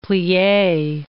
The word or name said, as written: plie